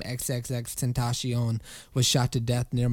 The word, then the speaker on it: XXXTentacion: XXX Tentacion is. The nom de rap is pronounced XXX tentacion, as in the Spanish word for temptation.